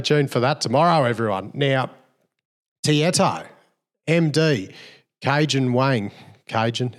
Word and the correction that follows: Kagan: Kagan, not Cajun the Spice.